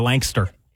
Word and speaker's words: Lancaster: That is a great kids' vacation, a kids' vacation that is centered around a little old traditional amusement park in, she said Lancaster. But, you know, I'm not allowed to say it like that. – Lancaster. It's like somebody who's tall and lanky. They're a Lankster.